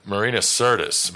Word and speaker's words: Marina Sirtis: And Marina Sirtis, am I pronouncing her name correct? – Mm-hmm. I believe so, yep.